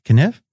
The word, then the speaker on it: Knijff: How would you say that? Kniff? Kniff like Knish from Rounders.